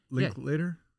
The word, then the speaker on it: Linklater: And Richard, what, Linklater? Say his name right? Famous director, the guy that did Linklater.